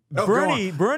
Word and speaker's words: Boerne: Bernie is spelled. You'd think it's spelled B-E-R-N-I-E, but it's actually spelled fucking B-O-E-R-N-E. – Yeah. – Born. – It's Borny. – And then there's Born. Then there's oh, let's go to Manor, Texas. M-A-N-O-R.